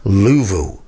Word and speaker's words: Louisville: And she's from Louisville, Kentucky, which to locals of that particular town is pronounced Louvoo.